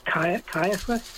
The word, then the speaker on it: Caiaphas: Is it Caiaphas mm-hmm.